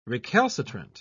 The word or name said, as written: recalcitrant